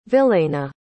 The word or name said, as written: Vilayna